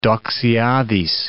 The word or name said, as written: Doxiadis